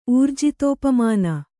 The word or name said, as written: ಊರ್ಜಿತೋಪಮಾನ